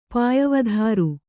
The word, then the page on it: ಪಾಯವಧಾರು: pāyavadhāru